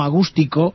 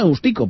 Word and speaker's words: Agustico: No te me vayas, José Manuel, porque ya digo que además de la música, este festival, en este Agustico o Agústico, sería Agustico porque no lleva tilde, entonces diremos Agustico, pero que también puede ser Agústico.